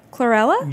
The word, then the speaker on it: chlorella: But you also talk about superfoods like fermented vegetables, turmeric, and one that I didn't know, which was the chlorophyll powder, the algae, Cholarella. – Chlorella? – Yes, that's the one. Butchered that well.